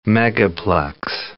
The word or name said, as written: megaplex